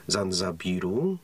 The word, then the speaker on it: Zanzibar: Stąd ta nazwa Tanzania to y, część y, brytyjskiego Zanzibaru, tak to się y, wymawia.